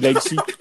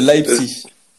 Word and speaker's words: Leipzig: Ouais, mais on dirait que tu dis Leipzig. tu prononces pas le chi. Si, le chi. T'as pas entendu le chi Non, j'ai pas entendu. – c'est Leipzig.